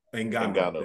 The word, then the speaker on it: Ngannou: So yeah, Tyson Fury won decision, split decision against I can't say his name, Francis Ngannou. – Ngannou, there you go.